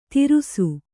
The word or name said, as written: ತಿರುಸು